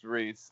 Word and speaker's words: Reese: My name is Tribble Reese. Reese works too, but so it's pronounced Reese, like the candy.